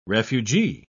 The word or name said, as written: refugee